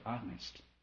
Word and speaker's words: honest: Our experiences, you will get the answer, if you're honest. H-O-N-E, you know, H is silent.